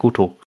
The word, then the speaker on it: Goto: Die Goto. – Goto. – Einfach Goto. – Goat. – Er muss es halt so japanisch aussprechen, dann ist es halt Goto.